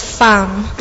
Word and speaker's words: femme: I think most people will call me what Kelly will pronounce as femme. – Okay? Yeah, because it's a French word.